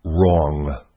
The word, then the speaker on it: wrong: I want to talk about wrong. W-R-O-N-G. Wrong. Wrong is an odd word, right? The W is silent, but the impact of the word wrong usually isn't.